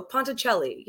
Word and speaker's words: Ponticelli: And did I get your last name right? Did I pronounce it right? Because that's what I tried to take pride in. – Well, yes, I believe so. Ponticelli. Yeah. Ponticelli, it sounded great.